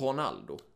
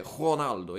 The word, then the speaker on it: Ronaldo: eller typ då, och har jag fått lära mig av en brasiliansk kompis, att egentligen det riktiga uttalet på Ronaldo är Ronaldo. – Ja. – Alltså, i, på portugisiska som man snackar i Brasilien så säger man inte R, utan man säger R. Så det är Ronaldo egentligen för Ronaldo.